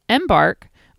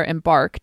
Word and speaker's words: Embark: Embark, or Embark, depending on how you prefer to put the emphasis on your syllable.